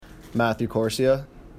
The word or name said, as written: Quercia